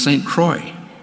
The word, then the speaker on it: St. Croix: And it's spelled C-R-O-I-X. Why don't they say St. Croix, but it's St. Croix.